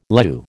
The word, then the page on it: leu: American English